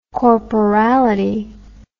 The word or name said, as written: corporality